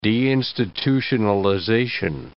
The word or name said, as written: deinstitutionalization